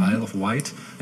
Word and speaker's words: Isle of Wight: Habe ich das richtig gesagt? Mhm. Isle of Wight, ja auf der englischen äh, Ärmelkanalinsel Isle of Wight traten vom 27.